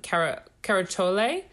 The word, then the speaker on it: Querciole: And they had a wine called, I'm probably going to pronounce it wrong, but I think it was pronounced Caracciole. It's spelled Q-U-E-R-C-I-O-L-E.